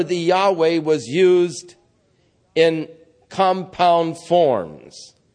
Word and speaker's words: Yahweh: There are those who say Jehovah. There are those who say Yahweh. All we have are the consonants, YHVH. They left out the vowels so that we do not know what was the actual pronunciation. But most scholars conclude that it was Yahweh.